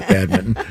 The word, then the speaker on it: badminton: See, badminton. That's how it's pronounced. – Right. Badminton. – But it's spelled badminton. – Exactly. – Oh, so what happened to that other end? – Well, they dropped it.